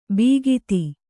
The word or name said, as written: ಬೀಗಿತಿ